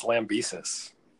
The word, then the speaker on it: Lambesis: Is that how you say his name? – I think it's Lambesis.